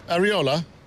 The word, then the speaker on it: Iraola: Iraola.